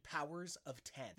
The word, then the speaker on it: Powers of X: While both are written and stylized as House of X and Powers of X, they are properly pronounced House of X and Powers of 10.